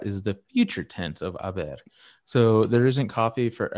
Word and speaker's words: haber: Yeah, so I is the present tense form of haber, there is, with a silent H.